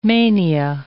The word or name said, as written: mania